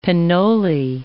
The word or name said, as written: pinole